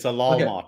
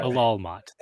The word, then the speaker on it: Alalmoth: Okay, that one, Calypso, you're really going to have to learn to pronounce properly. It's Alalmoth. Okay. You need to learn that. – Alalmoth. – A-L-L.